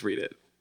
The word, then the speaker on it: Reddit: That you pronounced the show wrong. – Go ahead. – I thought that was funny. – What? Reddit? – Yeah. It's Reddit.